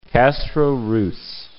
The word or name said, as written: Castro (Ruz)